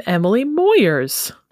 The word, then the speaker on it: Emily Moyers: And I'm Emily Moyers. – And Emily Moyers rhymes with lawyers.